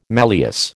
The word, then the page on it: malleus: American English